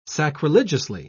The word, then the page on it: sacrilegiously: British English